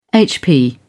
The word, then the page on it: hp: British English